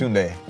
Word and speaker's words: Tunde: His parents and friends called him Tunde. – Tunde? How do you say it? – It's Tunde. – Tunde.